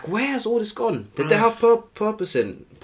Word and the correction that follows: porpoising: How do you say, by the way? – Porpoising. – Yeah. I keep saying purposing.